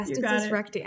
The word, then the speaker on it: diastasis recti: Let's talk a bit about, is it diastasis recti? So am I pronouncing that correctly? – Yeah, diastasis recti.